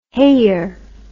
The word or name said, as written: heyer